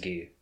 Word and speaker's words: Guy: So in the English, it's still spelt G-U-Y, but it's pronounced Gi. So that confused me at first. I'm like, who's Guy? Who, they didn't even say Guy, but they say Gi.